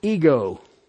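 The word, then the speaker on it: ego (ἐγώ): The word is spelled Epsilon Gamma Omega. It's always translated into English as a personal pronoun. It's pronounced ego.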